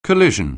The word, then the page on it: collision: anglais